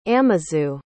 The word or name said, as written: amazu